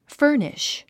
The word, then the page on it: furnish: fə́ː r niʃ; ファニッシュ